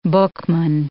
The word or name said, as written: bookman